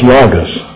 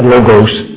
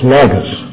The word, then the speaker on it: Logos: Fellows, please pronounce that correctly. It's Logos. Not Logos. It's Logos. And the Logos was with God.